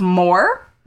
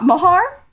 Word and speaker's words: Moher: It's the Cliffs of Moher, not Mahar.